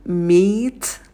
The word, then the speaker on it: meet: Потом «meet», «met», Мэт. На звуке «м» посидите подольше, потяните его. Потом долгий «и», «meet», и на «т» добавляем придыхание.